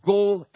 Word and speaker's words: Goel: Now, when you look at the Hebrew word, the redeemer word is actually in Hebrew called Goel. G-O-E-L. That's how the way you pronounce it in Hebrew.